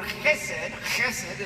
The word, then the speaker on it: chesed: So, it's in Hebrew Scriptures, the word chesed, and I pronounce it that way, Hebrew, is an unmerited love.